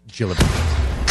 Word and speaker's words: Gillibrand: Here's soft G or hard G? I don't remember. – Soft. – Soft. Gillibrand.